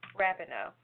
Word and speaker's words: Rapinoe: Rapinoe.